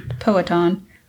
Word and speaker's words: Powhatan: Powhatan.